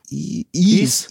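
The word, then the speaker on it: Ys: Diese Serie, die nur ein Y hat. Ist. Ease, Ease, genau.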